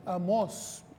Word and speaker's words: Amós: No, Amós, tiene un acento en la O.